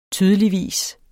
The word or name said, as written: tydeligvis